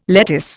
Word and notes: lettuce: Indian

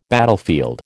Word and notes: battlefield: American English

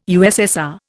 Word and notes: USSR: Indian

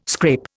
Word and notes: scrape: Indian

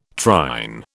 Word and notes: trine: British English

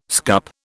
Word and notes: scup: British English

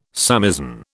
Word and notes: samisen: British English

